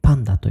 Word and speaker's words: 熊猫: えー、この漫画はですね、 BL 漫画家のパンダ先生、熊猫と書いてパンダと読みます。